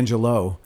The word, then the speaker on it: Angelou: By the way, how does she pronounce her name properly? She says Maya Angelou, but a lot of people in the film say Maya Angelou. – I know. It's kind of funny. It's Maya Angelou.